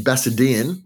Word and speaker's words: Bassendean: But now you've finally opened your own bar, Bertie, in Bassendean.